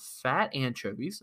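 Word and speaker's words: Phat Anchovies: First, I would love to shout out a one of my friends is actually in a band, and it's called Fat Anchovies, and that's spelled P-H-A-T Anchovies.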